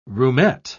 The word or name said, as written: roomette